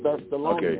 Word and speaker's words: Thessalonians: Okay, so Tessa Lanios, I guess I said that wrong, 1:4-18 says, but we do not warn you to... No, I don't think that's it. You said that wrong, too, so get yourself straight. – Okay. – All right, Thessalonians. That's right.